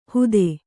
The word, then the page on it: ಹುದೆ: kannada; hude